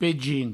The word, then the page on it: pegin: provencal